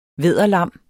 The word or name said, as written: vædderlam